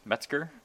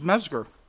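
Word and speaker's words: Mezger: It's M-E-Z-G-E-R. – Well, how do they normally spell it? – Oh, they're usually with a T, Metzger. – No, it's Mesger.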